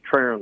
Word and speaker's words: Trahern: Yes, Trahern, like Aaron.